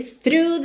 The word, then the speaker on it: through: Atenção à pronúncia dessa palavra. Eu sei que muita gente se assusta quando vê essa palavra por escrito, T-H-R-O-U-G-H. Mas começando pelo fim, o GH você ignora completamente, tá?